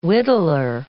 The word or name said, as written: whittler